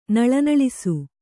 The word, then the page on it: ನಳನಳಿಸು: kannada